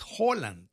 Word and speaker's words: Haaland: No es Haaland, es Haaland. Es también como una O.